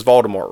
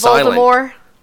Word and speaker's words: Voldemort: Which, by the way, everyone apparently pronounces Voldemort wrong. You're not supposed to pronounce it with a T.